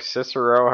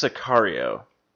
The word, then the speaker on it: Sicario: So I watched that, and then I watched Sicario or Cicero, however the hell you pronounce it. – I think it's Sicario.